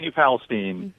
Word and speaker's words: New Palestine: How do you say New? Is it New Palestine? – I say New Palestine.